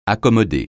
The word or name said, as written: accommoder